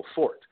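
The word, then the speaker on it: forte: So it was pronounced fort, and in English, fort. You pronounce the T, one syllable, fort. Now, the E got added, but it was still traditionally pronounced fort.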